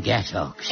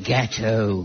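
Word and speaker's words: gâteau: Oh, you mean gatto, gatto. It may look as if it should be pronounced ox, but in fact, one says oh.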